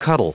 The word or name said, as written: cuddle